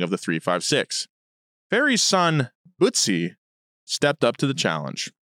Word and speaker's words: Butzi: Butzi, it's spelled B-U-T-Z-I. – Butzi.